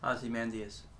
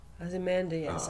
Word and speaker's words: Ozymandias: Oh. You said Mandeus. – Ozymandias. – Ozymandias. Oh, Ozymandias. Right.